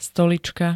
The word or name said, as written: stolička